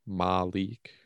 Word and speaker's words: Maleak: Malik spelled M-A-L-E-A-K. Malik.